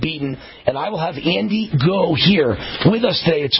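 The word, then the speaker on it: Andy Ngo: Andy Go. I think that's how you pronounce it. The N is silent. N-G-O. Andy Go.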